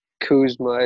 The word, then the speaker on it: Kuzma: Kuzma.